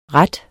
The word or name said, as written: ret